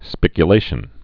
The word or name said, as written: spiculation